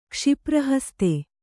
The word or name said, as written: ಕ್ಷಿಪ್ರಹಸ್ತೆ